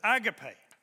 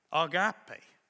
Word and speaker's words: agape: Well, many of us pronounce that as agape. But the Greek pronunciation is agape.